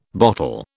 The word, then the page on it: botel: British English